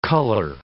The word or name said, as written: color